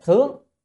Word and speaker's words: thướng: À, cái là gì, như thướng, cái chữ này không phải đọc là thượng mà phải là thướng. Thượng là cái chỉ ở trên, còn đây là thướng là đi lên.